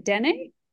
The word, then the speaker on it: Dene: I should have asked you how to pronounce that correctly. Is it Dene? – That was pretty good.